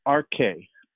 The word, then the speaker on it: arche: But the word principality literally is the Greek word that Paul chose to use there, is the word arche. Arche. That's literally how you say it. A-R-C-H-E.